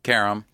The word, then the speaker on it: Karam: Karam.